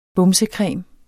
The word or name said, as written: bumsecreme